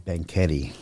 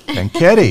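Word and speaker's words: Banquete: How do you say that? – It's called Banquetti. – Banquetti.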